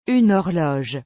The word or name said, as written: une horloge